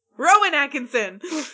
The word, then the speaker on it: Rowan Atkinson: Roman Atkinson is a treasure. – We'll have an episode about Roman Atkinson. – Rowan Atkinson!